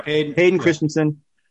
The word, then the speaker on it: Hayden Christensen: How do you say his name? – Hayden Christensen. Yeah. – Hayden Christensen.